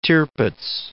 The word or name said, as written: Tirpitz